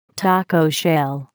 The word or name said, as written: taco shell